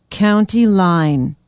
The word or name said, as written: County Line